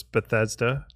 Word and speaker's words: Bethesda: So there's Bethesda.